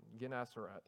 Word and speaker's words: Gennesaret: I looked up that word to how to pronounce it because it was messing me up. Yeah, Gennesaret. I think that's how it's supposed to be.